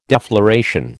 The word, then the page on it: defloration: American English